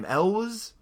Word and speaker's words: Elwes: Right, yeah, but I don't, how do you say his last name? Elwes? – Elwes. – Elwes, just yeah. A silent W.